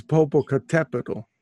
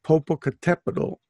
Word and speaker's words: Popocatepetl: Oh, because it's Popocatepetl. You have to put the accent on the Tepetl, that means mountain. Yeah, so Popocatepetl, and it's next to Iztaccíhuatl.